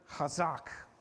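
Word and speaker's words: chazak (חזק): This is from the Hebrew word chazak. It's kind of got that phlegmy H at the beginning, right? It's spelled C-H-A-Z-A-Q. Chazak.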